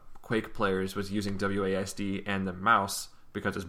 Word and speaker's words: WASD: I just call it WASD. I just compress it into one like a syllable word.